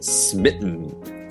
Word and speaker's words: smitten: S-M-I-T-T-E-N. – Right, so that sm sound at the beginning might be a little difficult to pronounce, but you can just...Slowly, slowly, smitten, smitten, put them together.